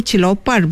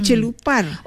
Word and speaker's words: celopar: Mulut kamu celopar benar. Celopar ya, bukan celupar. – Oh, ejaan sebenarnya celopar. – C-E-L-O-P-A-R.